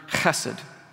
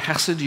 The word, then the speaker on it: chesed: In Hebrew, this is the term chesed. Kids, if you practice the word chesed, you know you're saying it right when you spit while you say it.